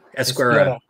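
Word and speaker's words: Ezquerra: That's the Carlos Esquerra. Yeah, okay. Thank you for saying it properly.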